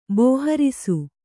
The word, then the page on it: ಬೋಹರಿಸು: Kannada